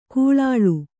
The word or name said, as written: ಕಳ್ಳಾಳ್